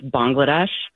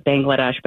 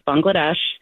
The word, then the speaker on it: Bangladesh: Bangladesh. – Yeah, I'm actually going to correct you, too. It's Bangladesh. – Oh. – Most Americans pronounce it Bangladesh, but it actually is Bangladesh.